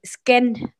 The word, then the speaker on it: Skene: que se le dice de Sken, Skene, así se escribe, ¿no?